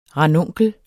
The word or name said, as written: ranunkel